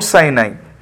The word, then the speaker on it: Sinai: Do you pronounce it as Mount Sinai? Mount Sinai, is my pronunciation right? – Yes, yeah. – Yes. – Yeah, Mount Sinai.